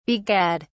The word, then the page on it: begad: British English